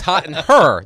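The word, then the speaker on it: Herre: Yes, because it's H-E-R-R-E. There's an extra R. It's Hot in Her, not Hot in Here.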